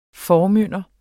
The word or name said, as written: formynder